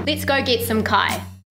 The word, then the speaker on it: kai: Kai, food! And we better put inu in there as well, drink. So let's start with the word kai. K-A-I, said with the Māori vowel sounds.